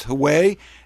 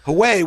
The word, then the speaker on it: Huawei: spying on a company called, I think it's pronounced Huawei, H-U-W-E-I, which is the biggest maker of everything from cell phones to computer systems to software systems.